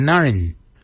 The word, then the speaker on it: NGARINY: Your ribs is your narin. Narin is your ribs. Narin is spelled N-G-A-R-I-N-Y.